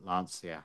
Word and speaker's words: Lancia: You know, there's an absolutely magnificent book about Lancia, and it talks about how Lancia, and it's the engineer that Lancia had, or Lancia, you should pronounce it correctly, shouldn't yes.